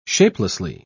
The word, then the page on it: shapelessly: British English